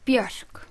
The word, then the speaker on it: Björk: Even though most English speakers say Björk, it's not really Björk. – It's Björk. – Björk. Björk. – Yeah. I usually say it rhymes with jerk. You know, someone's a real jerk. And that sort of Björk.